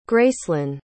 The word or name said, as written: gracelyn